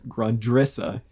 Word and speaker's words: Grundrisse: Directly after quoting the Grund, Grundrisse, which up until a couple days ago I was pronouncing Grundrissa.